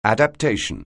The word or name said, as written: adaptation